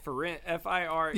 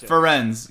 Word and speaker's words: Firenze: Firenze. – Yeah. Yep. Firenze.